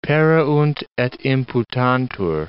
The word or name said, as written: pereunt et imputantur